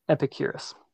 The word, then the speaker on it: Epicurus: And this is by, I'm going to pronounce this incorrectly, Epicurus. – Epicurus.